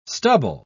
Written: stubble

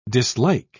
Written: dislike

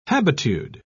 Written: habitude

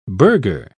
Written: burgher

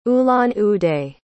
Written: ulan-ude

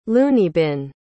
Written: loonybin

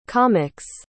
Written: comic's

